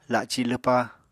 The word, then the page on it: laci lwpa: Apatani